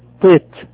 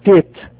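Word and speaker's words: طيت (טית): قلنا اليهود السفرديين بينطقوه طيت طيت، اليهود الاشكناز اللي هم الغربيين بينطقوه تيت تيت.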